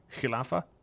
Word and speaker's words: khilafa: And when you say a caliph or the khilafa, right? Am I saying that right? Khilafa? – Yeah, well, khilafa or caliphate, that's the state itself.